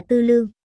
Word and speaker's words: tư lương: Viết là tư lượng, đọc là tư lương.